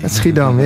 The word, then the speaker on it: Schiedam: Schiedam, yeah.